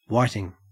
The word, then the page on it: whiting: Australian English